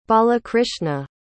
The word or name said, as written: balakrishna